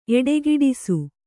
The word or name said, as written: ಎಡೆಗೆಡಿಸು